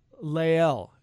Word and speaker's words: Lael: And I think it's Lael is how you say it, I believe.